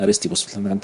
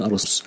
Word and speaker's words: أريستيبوس: يبقى في واحد اسمه أريستيبوس، أو يعني بيعرب، يقولون عنه العرب تقول عنه أرستيفوس، لكن هو اسمه أصلا أريستيبوس. تعرفون العرب لما بتعرب الأسماء أو بتعرب حتى يعني سواء أسماء الناس أو أسماء الأشياء، يعني إيه بتحرفها قليلا تكون أقرب لإيه، يعني أقرب لسانها وطريقتها في النطق. يعني هو اسمه أريستيبوس لكن يقولون أرستيبوس.